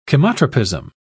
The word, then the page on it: chemotropism: British English